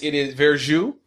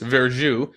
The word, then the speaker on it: verjuice: It is verjuice, pronounced verjuice, is the tart, unfermented juice of unripe wine grapes with high acidity and a tart apple-like flavor.